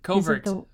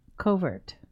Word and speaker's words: Covert: Covert, like the mission. – Covert.